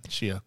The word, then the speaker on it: Shia: How is it? – Shia.